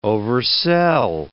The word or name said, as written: oversell